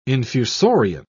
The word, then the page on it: infusorian: British English